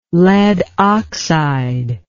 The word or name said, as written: lead oxide